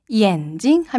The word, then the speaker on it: 眼镜: 안경 하면요, 眼镜, 镜이 사성으로 발음이 나요. 그런데 경성으로 발음하면 眼镜 하면 눈이 되는 거거든요.